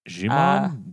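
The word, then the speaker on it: Djimon: Jimon? Demon? – Probably. – Silent D? – Yeah, I think so.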